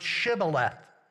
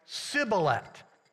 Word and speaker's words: Shibboleth: And those from Ephraim pronounced a certain Hebrew word differently than those from Gilead, and the word was Shibboleth. The Ephraimites had trouble pronouncing the SH, so when they said the word, it sounded like Sibboleth.